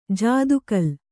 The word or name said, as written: ಜಾದುಕಲ್